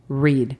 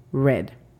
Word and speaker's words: read: Repito, leer en presente es read. La pronunciación fonética es R-I-D, read. Ojo ahí con la pronunciación de la R, read. Si queremos decir leído o leyó, entonces la pronunciación fonética cambia a R-E-D, read.